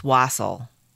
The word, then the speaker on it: wassail: Okay, the word wassail, I kind of have to know if I'm saying it right. – Wassail. – It's wassail. According to Google Pronounce, it's wassail.